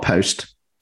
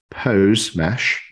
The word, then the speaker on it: posemesh: So, Auki Labs is creating a posemesh, not post, posemesh. Oh, did I pronounce that right? That's right.